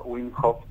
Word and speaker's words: Wim Hof: No, está muy bien pronunciado, método Wim Hof, como bien dijiste.